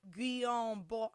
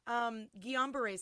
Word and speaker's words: Guillain-Barré: I read this and it's spelled like Guillain-Barré. I was like, wait a minute, that's French. It's probably like Guillain-Barré. Oh, shit, I know this. – Yeah. – Guillain-Barré syndrome.